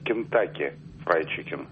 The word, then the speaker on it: Kentucky Fried Chicken: Вы неправильно произносите название компании. Они называются Kentucky Fried Chicken.